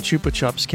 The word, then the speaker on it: Chupa Chups: What makes the pronunciation Chupa Chups if it's both the same spelling? – No, there's an S at the end of one and an A at the end of the other.